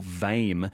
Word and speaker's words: VAME: Anyway, if you like miniature versions of things, you're in luck, because the annual show of the Victorian Association of Miniature Enthusiasts, or VAME, unless I'm mispronouncing that, is on. I'm getting heads being... Hang on, one of you's nodding, one of you's shaking your head. – Vame. – It is Vame? Vame. Great.